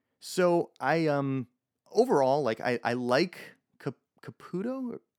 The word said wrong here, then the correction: Capullo: Is it Caputo or Capula? I'm gonna mispronounce the name too. – Capula. – Capula with an L. – Yes, two L's. – So Capullo, or it could be Capullo.